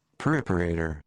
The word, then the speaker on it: preparator: Preparator. – Oh, preparator. See, that puts the emphasis on prep. – Yeah. Is that right, Caitlin? – Interesting. – I think it's more preparator.